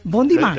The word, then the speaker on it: bon dimanche: Bon dimanche. – ¿Lo he dicho bien? – Très bien.